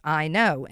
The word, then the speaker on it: Eino: It's the Professor Ino, E-I-N-O, and it's pronounced I know.